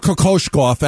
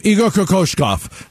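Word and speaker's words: Kokoskov: They went from 19 wins in 2018-19 with Igor Kokoshkov as the head coach. And I got so good at saying that name. Kokoshkov. Remember in the beginning we couldn't pronounce his name? Kokoshkov. Kokoshkov. Igor Kokoshkov.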